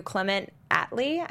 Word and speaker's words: Clement Attlee: Collins goes rogue and goes to Clement Attlee, I believe is the pronunciation.